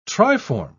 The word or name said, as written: triform